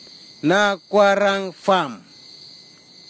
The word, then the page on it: nakwaragfam: kwamera; Tanna